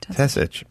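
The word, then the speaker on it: Tesich: Steve, is it, how do you say, how do we pronounce, written by Steve Tisich? – Tisich.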